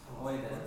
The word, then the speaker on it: Freude: And very clearly, it shows the first syllable is Freude. – Well, I was just waiting. That it is far more important than the second one, right? So make sure you're going Freude, right?